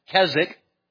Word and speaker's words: Keswick: Then there was the Keswick Convention, and that's Keswick, but don't pronounce the W.